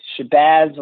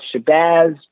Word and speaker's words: Shabazz: My name is Shabazz Larkin. You can pronounce Shabazz just like jazz.